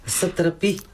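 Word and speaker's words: Satrapi: Сатрапи. Така да, ли е ударението? – Да, да.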